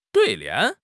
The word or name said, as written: 对联